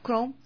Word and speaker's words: Chrome: Así se pronunciará, Web Chrome.